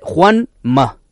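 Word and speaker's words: Juan Ma (皇马): Y a que no sabéis cómo se dice Real Madrid en chino, de verdad, no es un chiste. No es un chiste, porque además me dicen que han estado supervisándolo traductores chinos. – A ver. – Se dice Juan Ma.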